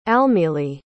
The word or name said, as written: almili